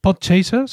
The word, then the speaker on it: Podchaser: Podchaser, ¿se pronuncia así, Juan? – Sí, sí, sí, efectivamente, eso te decía antes.